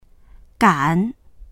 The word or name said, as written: gan3